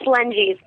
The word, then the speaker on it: Lengies: It's Lenges, but whatever you would say would be fine with me.